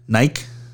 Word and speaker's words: Nike: How do you say if I spelled M I K E? – I'm not going to get into this with you. – Listen to me. Oh, my God. I spelled M I K E. M I K E. What does that say? – Mike. – If I spelled N-I-K-E, what does that say? – Nike. – Okay. – Yeah. – So it's Nike, first of all.